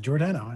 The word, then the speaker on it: Giordano: And I commend you on pronouncing my name correctly. I like that. Giordano.